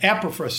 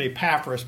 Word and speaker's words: Epaphras: Now, I'm going to say Epaphras, so, and we can agree on that's a 2,000-year-old word, maybe even older, so there's, who knows how it's pronounced, but Epaphras seems to be the accepted scholarly pronunciation. But I'm going to call it Epaphras because I've done that in here, and I don't want anybody to think it's two separate people.